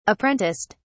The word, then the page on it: apprenticed: British English